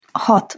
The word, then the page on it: hat: Hungarian